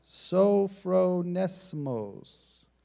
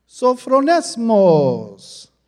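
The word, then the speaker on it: sophronesmos (σωφρονισμός): Well, there is a word, and it is pronounced sophronesmos. Sophronesmos.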